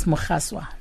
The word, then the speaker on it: Mogaswa: Yes, and my surname is Mogaswa.